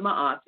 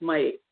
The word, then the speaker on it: Ma'at: not Ma'at, it's pronounced my age.